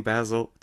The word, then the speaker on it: Basil: And as you said, it's Basil, not Basil. I'm British and we pronounce Basil as Basil. – We'll start from the very beginning, Basil.